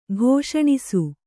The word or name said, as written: ಕ್ಷಣಶಃ